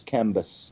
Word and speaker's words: Cambyses: John says it's Cambyses.